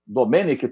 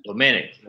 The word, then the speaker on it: Domènec: Domenech Torrente, acho que é isso que fala, né? – Domenech. – É, Torrente. É, a pronúncia parece que é essa.